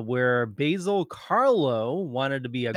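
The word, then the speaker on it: Karlo: So, Basil Carlo. Better. There you go. – It's pronounced Carlo.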